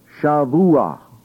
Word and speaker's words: Shavuah: That word is entirely different, and it's pronounced Shavuah or Shavuah. Kind of with a G, Shavuah, the end.